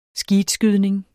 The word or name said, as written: skeetskydning